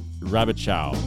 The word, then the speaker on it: Rabichow: Here's Kevin Rabichow.